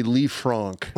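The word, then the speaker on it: Lisfranc: Technically, it would be a Lee, the technical pronunciation, it's a Lee Frank fracture coupled with a distal fibular fracture as well.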